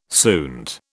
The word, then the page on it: suint: British English